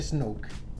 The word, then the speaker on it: Snoke: Como nieve, pero con K E al final. – Como snorkel, pero no. – Snoke. Es como Snoke.